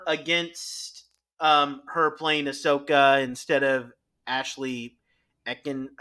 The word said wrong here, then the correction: Eckstein: Uh, I think it's Ashley Eckstein.